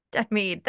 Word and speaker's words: Denmead: Denmead.